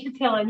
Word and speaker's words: Chatillon: Is that, did I say that right? – That's close enough, Jody Chatillon. Yep.